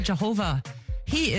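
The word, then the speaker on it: Jayhover: That was One Leg by Jehova. He is a U.S.-based artist from Ghana, also a well-known TikToker. His name is actually spelled J-A-Y-H-O-V-E-R, J-Hover, but it's pronounced Jehovah.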